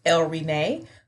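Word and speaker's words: Elle Renee: But in the styling industry, I use El Renee, which is a playoff of the first letter of my name.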